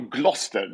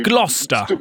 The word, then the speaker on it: Gloucester: Så det uttalas double Gloucester. Det är Gloucester. – Brittisk stavning. Gloucester. Så brittisk stavning är spännande alltid.